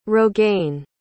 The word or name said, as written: rogaine